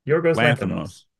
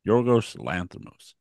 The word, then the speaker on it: Yorgos Lanthimos: Yorgos Lanthimos. – Yorgos Lanthimos.